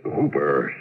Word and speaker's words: Houper: The name's Hooper, spelled H-O-U-P-E-R, but pronounced to rhyme with Cooper.